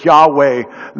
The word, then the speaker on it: Yahweh: He was a little unsure that this God who he says he was, I am that I am, in the Hebrew we pronounce it Yahweh, the name of the Lord, the Most High God.